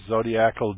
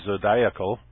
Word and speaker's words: zodiacal: There are rings, the zodiacal disk. Some people say zodiacal. I believe that's incorrect pronunciation.